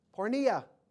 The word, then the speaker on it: porneia: Here's how you spell the Greek word. Transliterated, P-O-R-N-E-I-A. That's how you spell it. Pornea. That you should abstain from pornea, which is similar to the word porn.